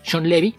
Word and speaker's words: Shawn Levy: Entonces, por ejemplo, un caso reciente, el director de The Adam Project, Free Guy y próximamente Deadpool 3, Sean Levy, se pronuncia así, tal y como suena. Este apellido es... existe también en América Latina, es Sean Levy,